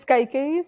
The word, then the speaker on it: Kaikeyi: But the book is called Kaiki. Yes. Pronounce it for me. – It's Kaiki, so you're getting it right.